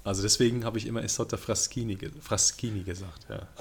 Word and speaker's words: Isotta Fraschini: Wirklich, also das CHI wird KI ausgesprochen, also Isotta Fraschini.